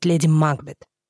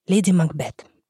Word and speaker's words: Макбет: Тут надо сказать, что в России лет 200 назад сложилась традиция произносить эту фамилию с ударением на первый слог, то есть «Леди Макбет». Вы можете услышать такой вариант произнесения этой фамилии, и он тоже считается правильным. Но мы будем все же говорить, как у Шекспира. «Леди Макбет».